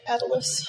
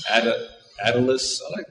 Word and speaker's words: Attalus: Attalus. – Attalus.